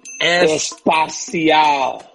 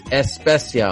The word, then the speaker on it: especial: He said, you're saying it wrong. It's especial, all right? He says he loves the show, but it's not especial.